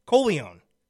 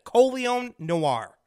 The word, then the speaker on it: Colion Noir: Col- Colion, Colion. That's it. Colion Noir.